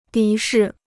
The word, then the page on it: 敌视: dí shì